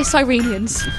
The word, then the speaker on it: Cyrenians: How do I say Cyrenians? – Just like that. That's Cyrenians. – Cyrenians, that's it. Yeah. – Am I saying that right? – That's absolutely perfect. It's taken me about 6 months to work out how to say it. And I work for the charity, which is embarrassing, but that's exactly it, Cyrenians.